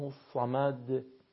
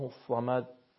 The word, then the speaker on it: الصمد: حرف دال بدید تا صدای حرف دال به گوش برسه، به این شکل بسم الله الرحمن الرحیم قل هو الله احد الله الصمد، یعنی نشه الله الصمد.